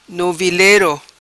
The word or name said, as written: Novilero